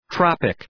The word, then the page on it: tropic: ‘trɒpık